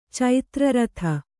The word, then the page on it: ಚಿತ್ರಾರ್ಧ: Kannada